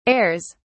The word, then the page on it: airs: British English